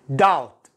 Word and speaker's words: doubt: doubt doubt البي لا ينطق نعم الفصيح أو الماهر نسميه ماذا؟